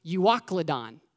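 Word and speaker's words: Euroclydon: So, in fact, the Greek word for Northeastern, listen to this, it's pronounced Euoclodon.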